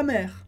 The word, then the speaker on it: mer: Então, aller vivre plus près de la nature, e morar mais perto da natureza, près de la mer, perto do mar. Lembra que mer, palavra que é mar, tem a mesma pronúncia de mère, que é mãe,